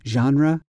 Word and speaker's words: genre: And it's spelled G-E-N-R-E. And at least in English, we pronounce that genre. A genre, like I said, is a category of story.